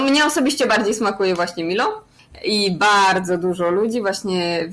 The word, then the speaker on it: Milo: chodzi o napoje, to chyba najbardziej popularne jest tutaj Milo, tak się y, wymawia, Milo tak jak po polsku by się przeczytało.